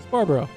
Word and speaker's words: Sbarbaro: How do I say your last name? – Sbarbaro. It's not hard.